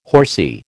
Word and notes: horsy: American English

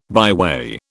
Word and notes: byway: British English

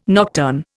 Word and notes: nocturne: Indian

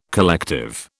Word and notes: collective: British English

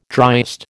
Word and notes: driest: American English

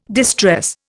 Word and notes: distress: Indian

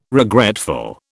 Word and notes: regretful: British English

